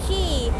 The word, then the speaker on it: Quay: คีย์สะกดหน้าตาแปลกๆนิดนึงนะ QUAY แต่คำนี้อ่านว่าคีย์เหมือนคีย์กุญแจแล้วค่ะ